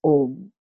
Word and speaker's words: Om: When you say Akaraha, Ukaraha, Makaraha, what I mean is A, U, M. And these three sounds constitute one single syllable. That is Om. Om is a syllable, single syllable consisting of three sounds.